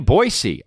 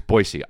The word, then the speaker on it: Boise: And then May 19th, I'm going to be in Boise. And that's how you say it. And whoever told me that was Aaron Paul. Aaron Paul told me. – Well, he's from there. – It's Boise.